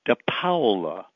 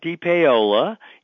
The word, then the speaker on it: DePaola: like T-O-M-M-Y. And my last name is DePaola, not DePaola, even though there's an A before the O. It's an Italian name, and Paola is a town in Calabria that my grandfather came from. And if you think of Paola as being P-O-W-L-A, that's how you pronounce the Paola part.